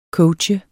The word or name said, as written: coache